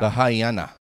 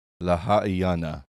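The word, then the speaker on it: Lahaina: La Haina. La Haina. You La have to Haina. Do every single letter. La Haina. – Because she was calling it La Haina. – Oh, La Haina. La Haina.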